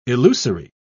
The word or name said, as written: illusory